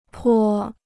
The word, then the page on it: 颇: pō